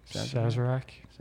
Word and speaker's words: Sazerac: Sazerac.